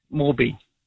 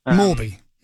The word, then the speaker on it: Morby: I like Will Jordan at fullback, and I like Carter, and I like I keep getting the name wrong. Morby. – Morby, yeah.